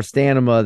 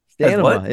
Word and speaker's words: stamina: Abby's in the backseat saying, damn, mom's ex had more stamina than dad. – Damn, stamina? – Stanima.